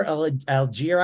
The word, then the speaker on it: Allgeier: Tyler Allgeier, I guess, is the way you say it. Is that how you say it? – Go for it. Yeah, you did good.